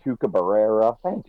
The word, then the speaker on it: Chupacabra: And so to try and instruct a New Yorker how to say El Chupacabra was really funny, especially trying to get you to roll your R's, because that's not something a normal person knows how to do.